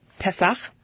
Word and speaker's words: Pesach: Well, enjoy your Pesach.